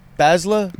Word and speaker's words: Basla: But I think I put an L in there. Basla? – No, you got it. Basla. – How'd you spell that? – B-A-S-L-A.